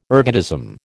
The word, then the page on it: ergotism: American English